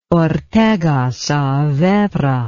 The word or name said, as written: ortega saavedra